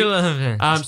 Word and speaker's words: Gyllenhaal: Gyllenhaal. – So he's producing. – How do you not know how to say Gyllenhaal? – Gyllenhaal. – Did you not spend your high school life obsessed with Jake Gyllenhaal? – No, I've never heard of him.